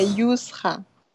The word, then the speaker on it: Yousra: Eu tô te chamando certo? Pronunciando o seu nome certo? Yursa? É, Yusra.